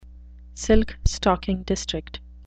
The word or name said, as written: silk-stocking district